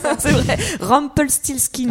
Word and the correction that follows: Rumpelstiltskin: Ah, non, il y a un T qui manque, c'est Rumpel Stilt. – Ah, voilà, Rumpelstiltskin. Et voilà.